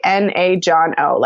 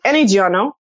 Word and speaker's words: Anaejionu: How I would say it to an American trying to pronounce it, I would say Anaejionu, like the letter N, letter A, name John, letter O. How you would hear a Nigerian person pronounce it would be Anaejionu.